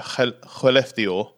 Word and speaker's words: Skellefteå: Skellefteå.